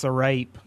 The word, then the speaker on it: sarape: I think it's pronounced Sarape. – Sarape, yeah.